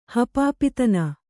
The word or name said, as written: ಹಪಾಪಿತನ